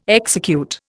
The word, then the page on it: execute: Indian